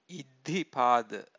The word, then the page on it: iddhipada: Pali